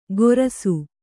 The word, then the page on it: ಗೊರಸು: kannada